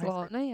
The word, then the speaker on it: Lautner: Taylor Lautner. – Lautner. What's how'd you say his name? Lautner. – I think it's Lautner.